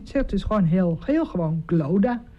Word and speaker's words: Clodagh: En daar stond Glodagh. – Zo spreek je het uit? – Nee. – Oh. – Je spreekt het dus uit als Glodagh.